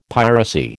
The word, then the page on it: piracy: American English